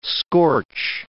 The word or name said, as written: scorch